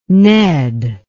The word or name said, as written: ned